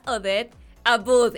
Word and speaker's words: Odette Abboud: Es mi querida Odette Abud. Ella me dijo que se dice así.